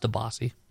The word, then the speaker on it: DiBiase: It's pronounced DiBiase.